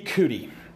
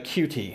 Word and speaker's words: Coutee: Interesting with Keke Coutee, uh, Cutie, however you say it. I'm probably butchering his name, but um, Keke was a player that was a very.